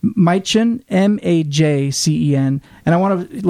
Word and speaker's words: Majcen: Last name is spelled M-A-J-C-E-N. Majcen, I think is pronounced.